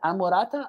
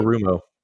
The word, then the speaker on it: Anarumo: And I will say, I never can pronounce his name correctly, but Amorata, the defensive coach that we have here, defensive coordinator. – Anarumo.